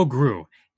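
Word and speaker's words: Groulx: Grew. It is spelled G-R-O-L-U-X, yes. But it's pronounced Grew, like the character from Despicable Me.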